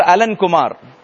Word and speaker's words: Alan Kumar: By name, how do we say that? Alan. Pray for Alan Kumar. Okay? A-L-A-N-K-U-M-A-R.